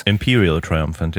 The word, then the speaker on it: Imperial Triumphant: Imperial Triumphant, ja.